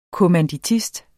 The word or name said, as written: kommanditist